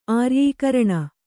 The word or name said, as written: ಆರ್ಯೀಕರಣ